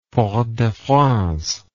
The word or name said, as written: Fort-de-France